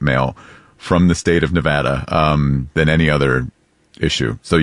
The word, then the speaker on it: Nevada: Because a lot of politicians would say Nevada. – Wrong. – And that is wrong. – It is Nevada. And I had to train myself to say Nevapple so I could pronounce the A right.